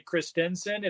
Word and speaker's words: Christensen: Christensen, it's really Christensen. It's a Danish name.